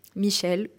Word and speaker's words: Michel: Donc Cédric, C-E-D-R-I-C. E Michel, comme ça se prononce, donc Michel, et tu fais sa méditation de pleine conscience.